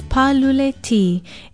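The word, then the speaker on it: pālule-T: Aloha, I'm Leilani Poliahu of Hawaii Public Radio, and our Hawaiian word of the day is paluleti. It is spelled P-A-L-U-L-E hyphen T with a kahako over the A.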